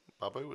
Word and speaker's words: Barbeau: Barbeau is the right pronunciation, I think.